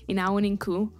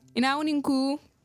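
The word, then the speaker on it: Ina wuninku: In Hausa, which is like the official northern language, you would say Inawaninku, which is like, just translates to good evening. – Oh, good evening. Okay. Yeah. So you say, hey, everybody, and good evening. – Yeah. – Say it one more time. – Inawaninku. – Inawinku. – Don't say it like that.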